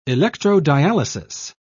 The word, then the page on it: electrodialysis: British English